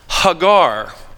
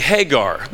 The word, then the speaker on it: Hagar: Her Hebrew name is pronounced Hagar. We would say Hagar, the same way we would say Abram when his Hebrew name is really Avram, and Sarai, and we'd say Sarah.